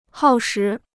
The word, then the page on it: 耗时: hào shí